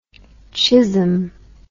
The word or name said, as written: chism